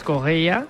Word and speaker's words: Correia: A pronunciarlo bien, Stassin, como nos han apuntado, y Félix Correia, como dice José, con muy buena pronunciación portuguesa.